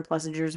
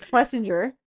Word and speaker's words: Plessinger: And then I probably miss like a great example of that was during Aaron Plessinger's win. I'm saying his name wrong. Plessinger, isn't it? Why do I keep saying the G? – Plessinger? – Plessinger.